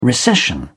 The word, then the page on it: recession: British English